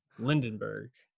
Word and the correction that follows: Lindbergh: You got it. Lindbergh. – Lindbergh. Oh, I'm sorry. I was like, am I saying it wrong? I was saying it wrong.